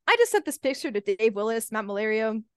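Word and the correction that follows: Maiellaro: is that how you say his name, Matt? – I think it's Malero.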